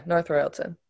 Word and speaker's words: North Royalton: Yeah, North Royalton.